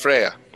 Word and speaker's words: Freya: Okay, so I'm not allowed to pronounce it Freya. It's Freya. No, it's Freya.